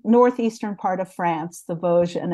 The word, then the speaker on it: Vosges: And then you go into, I don't know if I can pronounce this right, the Vosges. I didn't pronounce it correctly either for quite a while. But but it's the Vosges.